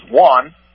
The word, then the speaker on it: Wuhan: Wuhan, the correct pronunciation of the word is Wuhan.